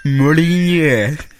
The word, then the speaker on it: Molyneux: Det är bara det att jag har all, alltid undrat hur fan karn ska uttala sitt namn. Molinje. Ja, Peter Molinje. Ja, bra. Exakt.